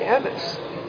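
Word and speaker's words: emes (אמת): So we say emes. We can't pronounce it any other way. But it's really a chatef a segol. Which is why it's emes, not emes. You know, the word is emes.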